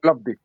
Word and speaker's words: Plovdiv: ¿cómo se pronuncia esto? ¿Plovdiv? ¿Plovdiv? ¿Plovdiv? – Albacete. – No, Plovdiv. Albacete. Es el Albacete de Bulgaria. Plovdiv.